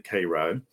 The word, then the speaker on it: Cairo: It's spelled like Cairo, Egypt, but we pronounce it Cairo.